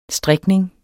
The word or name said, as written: strikning